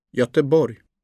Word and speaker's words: Göteborg: Soft G, place names. – Gerum, Gislaved, Gyttorp, Gäddede, Göteborg.